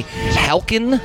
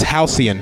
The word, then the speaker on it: Halcyon: No, you're totally wrong. – We are. – It's that's a good guess, though. I like your logic there. It's pronounced Halcyon.